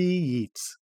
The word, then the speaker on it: Yeats: W.B. Yeats. It rhymes with Keats.